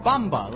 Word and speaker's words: Bamba: Bamba, like in samba.